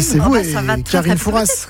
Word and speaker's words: Fouras: Fouras. – Je sais pas, on prononce pas le S apparemment.